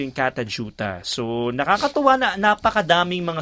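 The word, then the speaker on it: Kata Tjuta: Katajuta yun pa na i-pronounce siya. Pero pag in-spell siya, K-A-T-A, isang word na yun, tapos another word, T-J-U-T-A.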